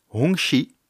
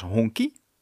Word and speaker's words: Hongqi: Namelijk Hongqi. Vaak misuitgesproken als Hongqi.